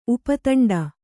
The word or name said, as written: ಉಪತಂಡ